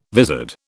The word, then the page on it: vizard: British English